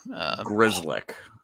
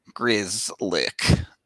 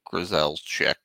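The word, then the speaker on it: Grzelcyk: Grizzlick. That's how you say it. Chad is telling us that that's how you say it. – Grizzlick. Not Grizel's chick or whatever.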